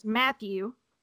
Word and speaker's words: Mathieu: First of all, it's Matthew.